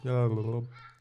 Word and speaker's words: Gal Gadot: Gal Gadot. It's Gal Gadot. We just pronounce it Gal Gadot so we sound cooler, but we're wrong.